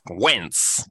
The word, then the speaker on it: Wentz: Carson Wentz is a complete trash bag of a quarterback. – I'm once again begging this podcast to pronounce the W in his name. – Yes, Wentz, command, take command.